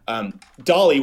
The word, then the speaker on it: DALL-E: DALL-E, which is spelled DALLE, like WALL-E the movie crossed with Salvador Dali.